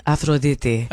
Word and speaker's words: Aphrodite: Should I say in the Greek pronunciation? Aphrodite.